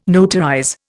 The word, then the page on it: notarize: Indian